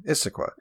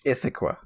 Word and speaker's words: Issaquah: And I see the two S's in there. Issaquah. – Okay. – But I swear, man, anytime I've heard you pronounce it, I've heard you say it with like a TH, Issaquah. – No, dude. – God damn. – Never.